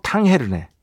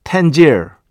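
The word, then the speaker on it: Tangier: 어, 아, 또 우리 김철영 PD가 이 모로코의 발음에 대해서 자기가 찾아보겠다 했는데 아 모로코가 스페인어로 탕헤르네. 포르투갈어로는 탕제흐. 프랑스어로는 탕제. 땅제. 땅제. 어. 영어로는 텐지르. 뭐 이 정도 되겠네요. 텐지르.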